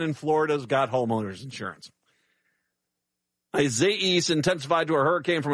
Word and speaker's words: Isaias: The E at the end of it is the big pronunciation, Isaias. Isaias. Okay. That sounds like an AIA in the middle of it.